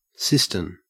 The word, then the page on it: cistern: Australian English